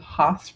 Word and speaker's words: Haus: On this week's episode of the Healthy Kansas City Podcast, we interview Zach Allen, who is the co-founder and co-owner of Hemp Haus, or Hemp Haus, rather, H-A-U-S. – Use the German pronunciation.